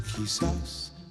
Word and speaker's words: quizás: Quizás, quizás, quizás.